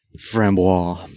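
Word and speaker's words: framboise: Oysters and a fambois, I can never say it right. – Frambois.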